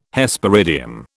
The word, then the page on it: Hesperidium: British English